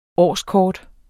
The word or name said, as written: årskort